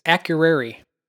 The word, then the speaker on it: Akureyri: So the other Icelandic port of call on these cruises is Akureyri. I hope I didn't butcher that. – No, that sounds about right.